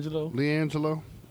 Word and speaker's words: LiAngelo: How do you say his name? – LiAngelo.